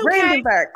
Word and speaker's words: Brandenburg: Brandenburg.